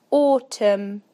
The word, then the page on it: automn: British English